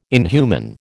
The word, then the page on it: inhuman: American English